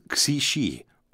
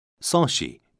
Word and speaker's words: Xishi: Later, the stream was named after the loyal subject, whose name was Xishi, or Soshi in Korean pronunciation.